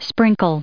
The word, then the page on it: sprinkle: verb